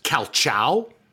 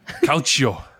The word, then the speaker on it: calcio: Isn't it pronounced calciao? – Calcio.